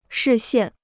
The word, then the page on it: 视线: shì xiàn